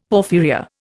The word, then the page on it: porphyria: Indian